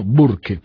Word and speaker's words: Burke: escrito B-U-R-K-E. Pronúnciese, si no se puede, de otra manera, en castellano, Burke.